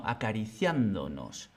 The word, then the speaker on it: acariciándonos: También acariciándonos acariciándonos. No decimos acariciándonos ni acariciándonos, sino acariciándonos.